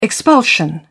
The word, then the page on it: expulsion: British English